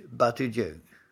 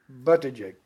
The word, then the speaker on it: Buttigieg: Ja, jag har bara sett namnet i skrift så jag törs inte på att uttala det. Kan du ta det en gång till? Pete Buttigieg. – Buttigieg. Så.